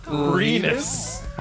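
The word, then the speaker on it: Rinus: Renus. – Oh, man, that's close. – R-I-S. – What rhymes with Renus? – Penis.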